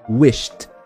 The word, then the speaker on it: wished: Então em vez de falar we shed, a gente fala wished, wished, t, wished. E vai repetindo.